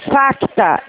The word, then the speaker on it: Facta: O outro banco se chama Facta. F de faca, tá? Facta. F-A-C-T de tatuá. O C é mudo, é Facta.